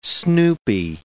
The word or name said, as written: snoopy